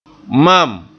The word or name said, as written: mam